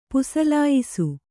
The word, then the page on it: ಪುಸಲಾಯಿಸು: pusalāyisu